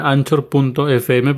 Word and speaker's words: Anchor: Ese es Anchor, se escribe anchor.fm, pero como está en inglés se pronuncia Anchor.